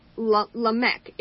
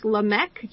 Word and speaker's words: Lamech: Lamech? – Lamech. It's the word that we use, that we derive the word lament. So it's Lamech, just like lament.